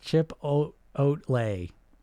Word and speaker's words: chipotle: Those the bagel cream cheese is pronounced chip oat lay. What'd I say? Chipotle? I know I say it wrong.